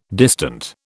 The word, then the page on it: distant: British English